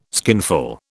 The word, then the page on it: skinful: British English